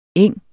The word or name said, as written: eng